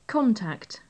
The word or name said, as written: contact